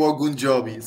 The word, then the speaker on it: Ogunjobi: Mas está, pá, não é o Ojungobi, como tu dizias. – Não é o Ogunjobi.